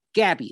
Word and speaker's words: Gabi: Gabby, it's spelled G-A-B-I.